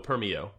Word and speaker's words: Permeo: P E R M E O Permio.